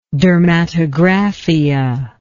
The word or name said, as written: dermatographia